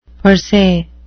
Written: per se

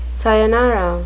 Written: sayonara

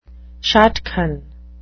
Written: shadchan

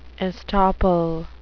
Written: estoppel

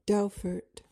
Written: dowfart

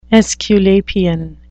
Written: aesculapian

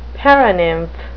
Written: paranymph

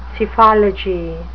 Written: psephology

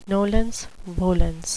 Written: nolens volens

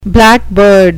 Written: blackbird

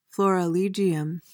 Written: florilegium

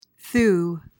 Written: thew

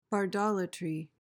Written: bardolatry

